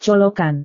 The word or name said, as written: colokan